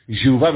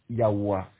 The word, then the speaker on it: Yahweh: Tout disent, Jéhovah, mais bon, c'est pas le vrai, la vraie prononciation. Yahvé, et encore mieux, Yahweh.